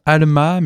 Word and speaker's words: VLMV: Avec un projet d'ambiance qui se prononce Alma, mais qui se lit V-L-M-V.